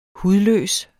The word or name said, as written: hudløs